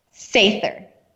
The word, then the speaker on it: Sather: So for all of my Southern friends out there, her last name is spelled S-A-T-H-A-E-R, correct? – No, S-A-T-H-E-R. So it's like father, but with an S instead of an F.